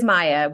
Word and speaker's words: Maja: It is Maja with a J.